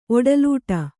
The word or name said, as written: ಒಡಲಿಡು